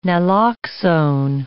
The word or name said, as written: naloxone